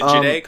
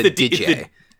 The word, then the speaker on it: Digeh: Is it Jideh, Colin? – No, it's you got the consonants backwards. – Oh, it's a DJ. – The DJ. – Yeah, the D-I-G-E-H.